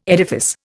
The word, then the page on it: edifice: Indian